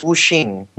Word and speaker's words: Wuxing: Is that how you pronounce that? Wuxing? – Yeah, it's Wuxing. – Wuxing. – Yeah, the X is kind of an SH sound.